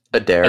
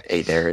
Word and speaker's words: Adair: And then the last thing I kind of want to hit, and you mentioned it a little bit, but the Patrick Adair, how do you pronounce your last name? I feel like I just butchered it. – It's Adair. – Adair, okay. – Adair, it doesn't matter.